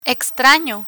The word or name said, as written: extrano